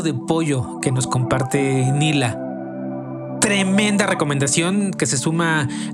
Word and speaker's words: Nela: Si la buscan, Nila se escribe N-E-L-A. N-E-L-A, Nela, pero se pronuncia Nila.